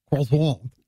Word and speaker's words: croissant: National Croissant Day. That's the proper pronunciation. Croissant.